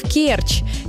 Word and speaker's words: Керчь: Один из таких случаев – Керчь. Нередко можно услышать вариант «в Керчи», но это ошибка. Правильно «в Керчи» с ударением на первый слог.